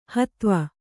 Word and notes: ಹುದುವು: huduvu

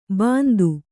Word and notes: ಬಂಧು: bandhu

